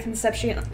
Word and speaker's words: Concepcion: Mallory Rubin and Jason Concepcion is how you say his last name, I believe.